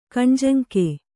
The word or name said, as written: ಖಾನಾಜಂಗಿ